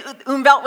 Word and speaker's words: Umwelt: Can you say Umwelt with me? Umwelt. So the W is like pronounced like a V in German. So it's your Umwelt.